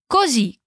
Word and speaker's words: così: De novo, agora atenção à pronúncia de sto, com S mudo, sto, e così, com ênfase no z.